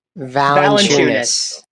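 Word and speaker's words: Valančiūnas: Valanciunas. – Thank you. Valanciunas, 29 points, 20 rebounds, 3 assists, and 4 blocks.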